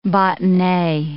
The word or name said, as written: botonee